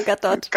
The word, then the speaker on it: Gadot: Das ist immer Gadot. – Gadot. – Gadot.